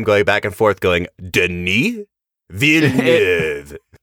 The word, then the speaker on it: Denis Villeneuve: We literally spent about, I don't know, 10 minutes before we recorded, like practicing Denis Villeneuve's name, because it is not easy to pronounce.